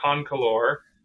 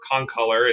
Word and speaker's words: concolor: Yeah, so mountain lions are Puma concolor. Or concolor, it's kind of pronounced differently depending on who's saying it, but basically sure.